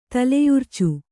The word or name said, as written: ತಲೆಯುರ್ಚು